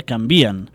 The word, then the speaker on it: canvien: Es tracta la frase a la cançó, en un moment que diuen les coses no canvien i és normal que ells la pronunciïn així, la paraula canvien, perquè els hi serveix per fer quadrar la rima i les síl·labes de les estrofes. Ara bé, el que volíem destacar aquí és que la pronúncia correcta de canvien seria canvien.